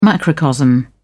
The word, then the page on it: macrocosm: British English